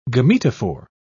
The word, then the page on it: gametophore: British English